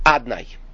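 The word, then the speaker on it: Adonai: That's the level of Aleph Dalet Nun Yud Adnai, as it's called, Adon, but Adnai as it's pronounced.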